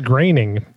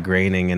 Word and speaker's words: Groening: Yeah, it's not spelled like Groening, and that's how you say it, and it makes me mad.